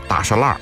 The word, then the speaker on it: 大栅栏: 其实呢啊北京人的发音啊就叫大栅栏啊。